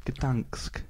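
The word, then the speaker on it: Gdańsk: Hello, everybody, and welcome to Wondering to Gdansk. – I got it right. – That's possible this time. – I think that's possible, isn't it? – That's possible. – Gdansk.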